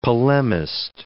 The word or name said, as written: polemist